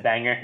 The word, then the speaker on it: Bangor: I finally pronounced it right after 20 months. – Amazing. – Said banger.